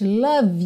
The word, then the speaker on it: love: Aqui, olha que interessante, love you, love you. Eu também ligo, porque o E de love não é pronunciado.